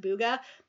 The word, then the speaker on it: bwogo: In East Africa, in the Luo dialect, the word buga, which is spelled B-W-O-G-O, but it's pronounced buga, it means to scare.